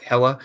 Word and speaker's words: Hela: You said Helena. It's Hela. Preview for Thursday.